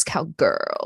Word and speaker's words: cowgrrl: My at is reverse cowgirl. Reverse spelled like reverse, COWGRRL. – Like girl. – Yeah, like reverse cowgirl, like riot girl.